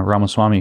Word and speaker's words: Ramaswamy: This is why, by the way, I got his name pronounced correctly now, Ramaswamy, who wrote Woke Incorporated, which you should read, is right that diluting their piss-poor values to irrelevance is a key component.